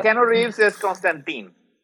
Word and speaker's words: Constantine: Pero bueno, muchas gracias, mi Oye, eh, ¿y ese era Constantine o Constantine o cómo se pronunciaba? – Constantine era, creo que la manera correcta, ¿no? Sí, porque. – Sí. Sí, Constantine es eh, la manera correcta.